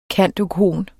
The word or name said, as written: cand.oecon.